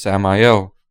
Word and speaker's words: Samael: Well, my name is Samael.